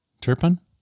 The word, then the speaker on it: Turpin: Including a free Yeti and Bones coffee, which is going to Turpine. – Turpin? – Turpin. Is that how you say it? – Yeah, well, that's how Turpin, we always said it in Kansas.